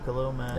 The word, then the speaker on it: Khalil Mack: Khalil Mack.